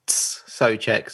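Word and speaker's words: Souček: Well, just to throw something further in there, my understanding is that it's pronounced Soucek. So it's like a T at the beginning, Soucek.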